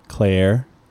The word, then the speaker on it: Cleyer: It's spelled C-L-E-Y-E-R. – Claire.